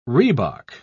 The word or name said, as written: rhebok